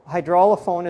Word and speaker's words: hydraulophone: Am I pronouncing the name properly? – Yes. So hydraulophone is the proper etymologically correct Greek.